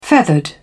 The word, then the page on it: feathered: British English